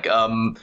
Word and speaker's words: Chiam: Look, I don't even know how to pronounce it myself, to be frank. Chiam. It's Chiam. It's exactly that.